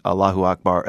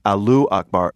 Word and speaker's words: Allahu Akbar: And I had to laugh again yesterday when the internet spotted several TV newscasters reporting on the New York attack, mispronouncing Allahu Akbar as Alu Akbar, which translates to what? – Potatoes are great.